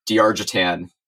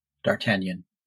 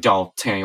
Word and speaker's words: D'Artagnan: And he has this little bit of business where every time someone says D'Argentan, he corrects their pronunciation of the name. – D'Artagnan. – Yeah. It's actually like D'Artagnan or something like that.